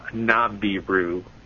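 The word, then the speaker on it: Nibiru: And but interestingly enough, under the old Aramaic name of Nabiru, and which is how it's pronounced in the Middle East.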